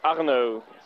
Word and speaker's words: Arno: My name is Arno. – Spell it for me, please. – A-R-N-O.